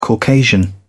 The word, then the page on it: caucasian: British English